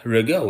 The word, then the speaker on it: Riga: So I was walking around Riga, which rhymes with nigga,